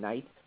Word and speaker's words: knight: We pronounce him knight. But back then, the word was pronounced knicht. So it's funny how that word changed so much from knicht to knight.